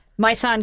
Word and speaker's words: Maison: Malson. No, I don't even know if I'm pronouncing these correctly. So Malson Givas. – Oh. Maison Givas, that is great stuff.